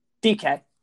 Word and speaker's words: Dike: And then I recently, I guess, found out that I'm pronouncing Daryl Dike? – DK. – DK. I've been pronouncing that wrong. I've just been saying Dyke for like a month.